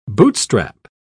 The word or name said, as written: bootstrap